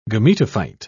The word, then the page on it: gametophyte: British English